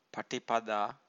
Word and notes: patipada: Pali

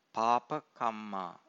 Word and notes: papa-kamma: Pali

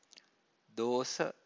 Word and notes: dosa: Pali

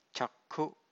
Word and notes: cakkhu: Pali

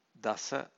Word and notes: dasa: Pali

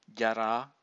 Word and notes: jara: Pali

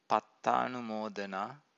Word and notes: pattanumodana: Pali

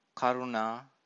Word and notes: karuna: Pali

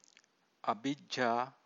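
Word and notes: abhijjā: abhijjhā

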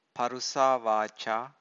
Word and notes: parusavaca: Pali

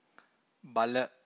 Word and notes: bala: Pali

